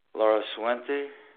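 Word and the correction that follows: Laracuente: Laraquente? – Yeah.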